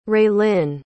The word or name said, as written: raelyn